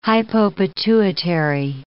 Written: hypopituitary

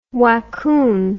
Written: waccoon

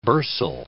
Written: bursal

